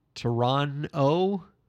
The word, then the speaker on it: Toronto: How do you say the city's name again? – Toronto. – Okay. We got work to do, buddy. – There's just like no T, apparently, according to you.